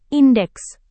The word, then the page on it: indeks: indonesian